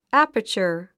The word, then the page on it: aperture: ǽpə r tʃə r; アパチャー